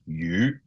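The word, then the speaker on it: yu (魚): So, for example, the Cantonese word for fish is yu, and it also sounds like the word for surplus.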